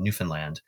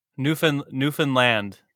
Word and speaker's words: Newfoundland: We flew up and made a stop in Newfoundland. And I have to tell you, that's how it's pronounced. Newfoundland. – Newfoundland. – Yeah. Like understand Newfoundland.